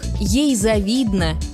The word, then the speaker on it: завидно: Согласно орфоэпической норме, ударение падает на второй слог. Ей завидно, ему завидно, нам завидно, отчего многие произносят неправильно.